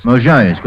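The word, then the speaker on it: Mojaisk (Можайск): Correct your pronunciation? – How do you Mojaisk. – Say Mojaisk. How long did it take you to learn it? – Well, I was in Russia twice. That's about all I did learn, that one word. – Mojaisk? – Mojaisk. – Mojaisk, with the accent on the second syllable.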